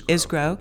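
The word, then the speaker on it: Isgro: Nick Isgrow. Is that how you pronounce his name? – I think it's Isgrow.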